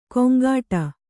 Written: ಕೊಂಕಾಟ

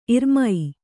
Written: ಇರ್ಮೈ

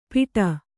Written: ಪಿಟ

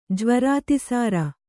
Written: ಜ್ವರಾತಿಸಾರ

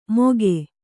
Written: ಮೋಘೆ

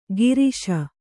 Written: ಗಿರೀಶ

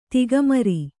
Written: ತಿಗಮರಿ

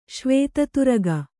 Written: ಶ್ವೇತತುರಗ

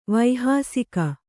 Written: ವೈಹಾಸಿಕ

